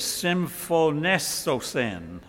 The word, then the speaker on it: symphonesosin: The Greek word for agree here is symphonesosin. I can't pronounce that too fast. Symphonesosin. It's the Greek word from which we get our English word symphony.